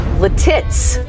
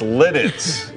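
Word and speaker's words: Lititz: No, it's Lidditz. – Lidditz. It's Lidditz. Not Letits.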